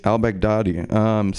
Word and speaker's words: al-Baghdadi: Al-Baghdadi.